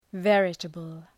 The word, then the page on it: veritable: ‘verətəbəl